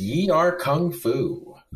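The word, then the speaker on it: Yie Ar Kung-Fu: So this is Yi-R Kung Fu.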